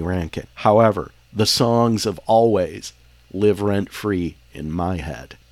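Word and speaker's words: Alvvays: Always spelled A-L-W-A-Y-S. Alves. There I go, spelling again. It looks like it should be pronounced Alves, but it's just a stylistic, let's just jam two V's together and pronounce it like it's a W.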